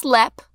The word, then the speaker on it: Leap: Pronounced Lep, spelled like leap.